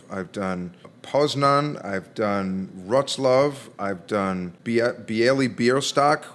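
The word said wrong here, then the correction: Białystok: Oh, isn't that the character from The Producers? That's Bialystok.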